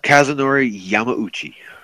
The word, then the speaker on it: Kazunori Yamauchi: Kazunori Yamachi, has revealed... I hope I'm saying that correct. – Oh, wait. I know how to pronounce his name. – Do it. Let me hear you. – Kazunori Yamauchi.